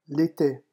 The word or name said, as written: l'ete